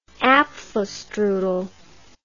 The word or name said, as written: apfelstrudel